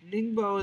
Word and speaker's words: Ningbo: Fresh off the Ningbo. Am I saying this correctly? – Ningbo is where it happened.